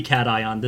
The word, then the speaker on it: cation: Alright, so first, I want to say the way you pronounced caution this episode, I thought you were making an Aethral Space joke. But second... – Oh, I'm sorry, it's supposed to be cation.